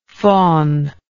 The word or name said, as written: fawningness